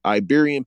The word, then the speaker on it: Iberian: These black people originated from Baran, I think that's how you say it, Baran Peninsula, Iberian. I didn't see that eye right there. I thought it was a parenthesis. Iberian Peninsula, who were actually the Moors, and are a fragment of the old Spanish population.